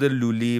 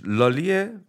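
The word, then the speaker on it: Lully: البته تلفظ انگلیسیش لالیه، ولی ما تلفظ فرانسویش رو میگیم لولی.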